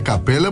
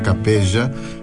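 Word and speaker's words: Capella: Eu vou dizer Capela porque eu vi Capela, são dois L's, mas acho que não se pronuncia Capeja, e sim se mantém a pronúncia mais próxima do italiano original.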